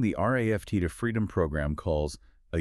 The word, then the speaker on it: RAFT: The program is called RAFT, and it's pronounced just like the word raft.